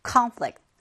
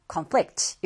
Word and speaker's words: conflict: 그래서 명사는 보통 앞에 강세가 오고 동사는 뒤에 강세가 온다. 하나의 단어가 이렇게 명사와 동사 의미를 동시에 지니고 있을 때 발음이 강세가 달라지는 단어들이 있어요. 그 중에 하나가 바로 conflict or conflict 이렇게 되겠습니다.